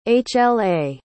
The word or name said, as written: hla